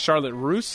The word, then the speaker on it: Charlotte Russe: Charlotte, I think it's pronounced Charlotte Russe. – Charlotte Russe, R-U-S-S-E.